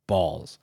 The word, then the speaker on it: Baals: Balls spelled B-A-A-L-S. – B-A-A-L-S. – Correct. – Okay. All right. – But it's pronounced Balls.